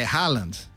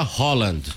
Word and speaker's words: Haaland: Como é que fala? É Haaland. Haaland, é, na verdade, a FIFA divulgou um vídeo em que os jogadores, eles dizem a pronúncia e ele fala Haaland. – Haaland. – Quase fica uma letra O, né? – Haaland.